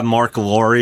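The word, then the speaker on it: Marc Lore: Thrilled to have Mark Lohr.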